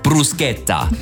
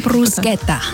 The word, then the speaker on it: Bruschetta: Und Bruschetta habe ich jetzt gelernt. – Als Vorspeise, genau. Das ist auch so ein Ding, wo Sie ein bisschen aufpassen müssen, dass Sie es richtig aussprechen. Bruschetta. – Bruschetta.